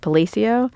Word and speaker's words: Palacio: Palacio.